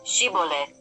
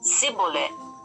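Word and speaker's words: Shibolet: Y si él, si él, respond, él respondía no, entonces le decían ahora pues, di Shibole. Y él decía Sibole, porque no podía pronunciarlo correctamente.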